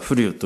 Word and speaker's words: 風流: そういうことなんですね、ね、まあ、風流というふうなそのことをですね、これは風流と書いて、実は風流というふうに言ってきたんですけどもね、その風流祭礼というふうなものは、そういうふうなですね、昨年よりももっと立派にというふうに、このね、大きくなったり上に上がったりすることがあるんです。